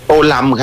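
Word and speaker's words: Tô Lâm: โตลัม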